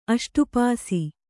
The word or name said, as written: ಅಷ್ಟುಪಾಸಿ